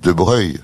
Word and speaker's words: Broglie: Je comprends, vous dites de Broglie, alors donc. – Oui. – C'est pas vrai parce que vous. – Normalement, dites... – les gens de famille disent De Breuil.